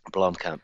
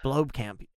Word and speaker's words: Blomkamp: And it's like I can't ever pronounce the guy's name right, Neil Blomkamp. – Blomkamp.